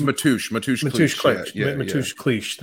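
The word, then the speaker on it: Mateusz Klich: Matthias Click, is that who it is? – I think it's Matouche Klich.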